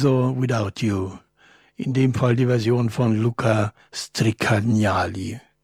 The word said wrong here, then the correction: Stricagnoli: Nee, wie heißt der Moment? Stricagnoli. Sorry.